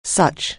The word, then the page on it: such: anglais